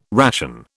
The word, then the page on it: ration: British English